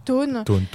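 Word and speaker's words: Toone: Par exemple, j'en ai parlé au tout début, donc il y a le théâtre de Thon, ou de Thun, je ne sais pas comment le prononce non plus. – Thon.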